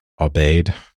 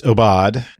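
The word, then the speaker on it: Aubade: I looked up the pronunciation. I don't think I pronounced it correctly when we announced it on the last episode. I might have thought it was obeyed. So anyway, it's obad.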